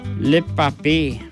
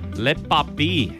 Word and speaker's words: le papier: Le papier. – Le papier.